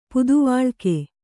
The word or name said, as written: ಪೊತ್ತುವೆಳಗು